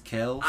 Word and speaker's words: Kelce: Did you see how everyone's been saying Travis Kelce's name wrong? It's Kels.